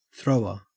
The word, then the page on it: thrower: Australian English